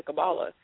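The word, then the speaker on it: Qabalah: I'm just kind of shocked that they think that Jesus was into Kabbalah.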